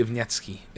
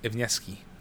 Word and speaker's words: Iwanski: Yours, Adam Badowski and Marcin Iwanski. – There you go with the Polish pronunciation. – No, I think it's actually Iwanski. Iwanski.